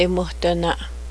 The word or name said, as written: it's breezy